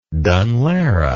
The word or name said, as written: Dun Laoghaire